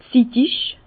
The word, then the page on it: zittig: Bas-Rhin; Herrlisheim